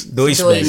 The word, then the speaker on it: Deutsche Bank: Correct me on this one, but also Trump's bank, the Schutz Bank. How's it pronounced? – I'm pretty sure it's Deutsche Bank.